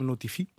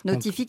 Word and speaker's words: Notiphy: Jusqu'à que moi et Benjamin Bois, mon associé, on n'avait pas eu l'idée justement de créer le système Notify. Donc un système qui permet de notifier la présence des pesticides à la parcelle. – Notify qui s'écrit N-O-T-I-P-H-Y, évidemment.